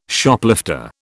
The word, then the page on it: Shoplifter: British English